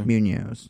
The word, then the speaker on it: Munoz: Munoz. Did I say it right? Munoz. Did I say it right? M-U-N-O-Z. Munoz. Okay.